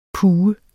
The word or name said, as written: puge